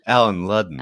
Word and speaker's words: Allen Ludden: Allen Ludden.